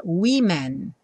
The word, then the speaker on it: women: Também a última sílaba é que muda, ao invés de woman com A, a gente escreve com E, mas curiosamente a pronúncia muda no começo e a gente fala women no plural.